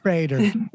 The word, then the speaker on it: Prater: It's like Prater.